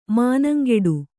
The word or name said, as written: ಮನಂಗಿಡು